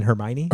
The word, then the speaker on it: Hermione: You mean Hermione? – Hermione. I don't know how to say her name. But like Hermione.